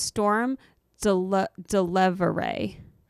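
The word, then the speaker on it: Stormé DeLarverie: Seems like there was one woman in particular, and it's believed to be Stormé DeLarvery. I might be saying that wrong. Yeah, I think I found a thing where it's where she explained how to, it's pronounced Storm Delevere.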